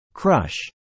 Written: crush

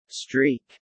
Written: streak

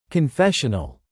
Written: confessional

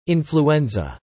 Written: Influenza

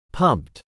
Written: pumped

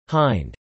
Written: hind

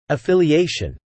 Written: affiliation

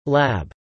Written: lab

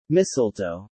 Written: Mistletoe

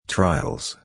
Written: trials

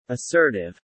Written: assertive

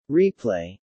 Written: Replay

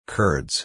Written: curds

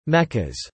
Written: meccas

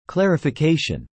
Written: clarification

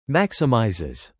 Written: maximizes

